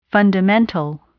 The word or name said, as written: fundamental